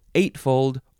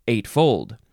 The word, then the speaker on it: eightfold: You can emphasize either syllable. Eightfold or eightfold.